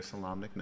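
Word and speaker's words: Solamnic: Salamnian? Did I say it right? – Salamic. Yeah, you're a Salamnic knight. Some people say Salamnic. But that sounds just a little bit too country for me.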